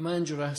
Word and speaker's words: Mandurah: Come from back from Mandurah. Is that how you're pronouncing that at the moment? – Right, Mandurah. Sunny Mandurah.